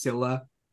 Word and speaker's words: Scylla: Now, Scylla could also be Scylla, but in English, supposedly, I was doing some research. It's pronounced Scylla, Scylla.